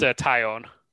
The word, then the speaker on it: Taillon: How do you pronounce it? – I think it's pronounced Taillon. – Sure. – Jameson Taillon.